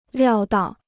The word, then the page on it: 料到: liào dào